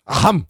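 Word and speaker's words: AHAAM: Aham.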